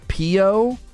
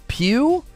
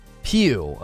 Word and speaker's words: Pio: Pio? Oh, it's neither of the pronunciations I went. It's Pew? Pew. Okay. Pew, like pew pew, like a gun goes pew.